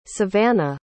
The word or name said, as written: savana